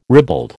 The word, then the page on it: ribald: American English